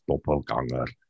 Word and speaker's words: doppelganger: Um, but the, uh, like the from a paranormal perspective, a doppelganger, and if we're going to do the correct pronunciation, it's German, it's doppelganger.